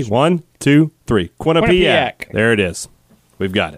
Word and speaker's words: Quinnipiac: Quinnipiac. – That's right. Did you just say it two different ways? – Quinnipiac.